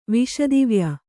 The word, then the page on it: ವಾಸ್ತವ್ಯ: vāstavya